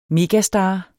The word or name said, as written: megastar